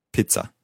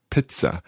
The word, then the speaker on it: Pizza: Ein Stück Pizza. – Pizza. I suppose we're more used to saying pizza, but pizza. – Ein Stück Pizza. – Pizza. So rhyming with bitza.